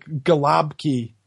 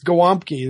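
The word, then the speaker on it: gołąbki: Yeah, it's spelled G-O-L-A-B-K-I, Galabki, but it's Gwomki. There's a W sound in there somewhere, and it means little pigeon in Polish.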